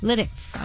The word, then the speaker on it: Lititz: Is this Lititz, Pennsylvania? Is that how you say it? L-I-T-I-T-Z, Pennsylvania. – I don't know. – Lititz, okay. And anyway. – It's French. It's Lititz. – Oh, yes. That sounds right.